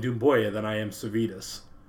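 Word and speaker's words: Doumbouya: So we talked about the main pronunciation is Dumboya.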